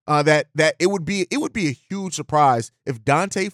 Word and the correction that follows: Deontay: Foreman is listed, or Deontay Foreman, I've been saying it wrong for a while, Deontay Foreman is listed as, ends up being one of the players that's cut today.